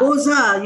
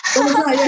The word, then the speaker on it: Beaux-Arts: They want to do something beyond books arts. – Beaux-Arts, you mean Beaux-Arts? – Yeah, Beaux-Arts. I don't know how to pronounce that. – Beaux-Arts, yeah, that's how you pronounce it.